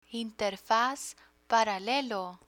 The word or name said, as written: interfaz paralelo